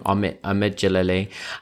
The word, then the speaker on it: Jalili: Jalali, I say his name wrong. Omid Jalili.